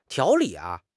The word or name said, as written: 条理